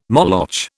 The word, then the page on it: Moloch: British English